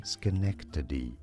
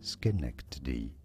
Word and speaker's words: Schenectady: Schenectady. Schenectady. Schenectady. – And this was under the personal call letters of Wendell King, an African-American student at the school. Also in October 1920, that same station in Schenectady,